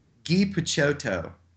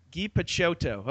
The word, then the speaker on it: Picciotto: How do you pronounce Guy's last name? – Guy Picciotto. – Guy Picciotto. Okay, I'm gonna go around saying that. – Picciotto. Something like that.